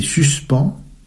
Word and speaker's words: suspens: Tu veux dire le suspense à la Hitchcock? – Absolument. Alfred Hitchcock expliquait la différence entre surprise et suspens, je prononce suspens, de la façon suivante.